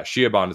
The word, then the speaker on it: Siobhan: It's a name that I, I it's just spelled oddly it's s-i-o-b-h-a-n do you know how to i know it's like a name that like has a common pronunciation but doesn't like look right spelling.